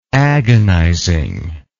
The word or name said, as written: agonizingly